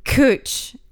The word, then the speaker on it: cwtch: And cooch, which is the vagina. – CWTCH is how it's spelled.